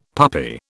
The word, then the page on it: puppy: British English